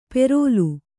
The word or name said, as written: ಪೆರೋಲು